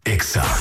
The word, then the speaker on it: EXA: No se dicen éxitos, se dice EXA.